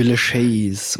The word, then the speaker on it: Villechais: Aber der Kleinwüchsige, der Schnickschnack, dessen Namen keiner aussprechen kann, Herr Villechais oder wie der heißt. – Herr Villechais. Es ist Franzose, Villechais.